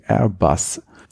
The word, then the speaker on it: Airbus: Und zwar für A320 Airbus. – Das hast du voll schön gesagt. – Ja, danke. – Ich hätte es auf Deutsch ausgesprochen, aber daneben ist ja eine amerikanische Flagge.